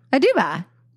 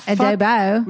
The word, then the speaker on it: Aduba: Is that how you say her name? Uzo Aduba. Uzo Aduba. – Yeah. – Oh. – Uzo Aduba. Aduba. – Aduba. Not Adobo.